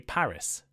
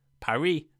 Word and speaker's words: Paris: The capital of France is Paris, but in English we say Paris. Why this difference? Well, in French they tend not to pronounce the S at the end of words, while in English we do. So naturally, English people, when they see the word Paris, they read Paris.